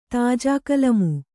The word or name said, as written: ತಾಜಾಕಲಮು